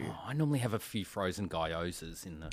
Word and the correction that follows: gyoza: Gyozas. – What? How do you say it? – It's like Guy Pearce, like gyozas.